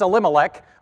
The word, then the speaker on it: Elimelech: But if you want to know how to say it, it's Elimelech, and then Moab and the Moabites.